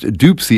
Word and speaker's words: Dopsy: This is Rockin' Dopsy Jr., or I suppose it's pronounced Doopsy, isn't it? That kind of makes sense, actually, now that I think about it, because this tune is called Doopsy's Boogie.